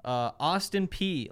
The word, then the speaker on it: Austin Peay: But the one game we do get, Central Arkansas is playing Austin Peay. Learned how to pronounce that earlier today.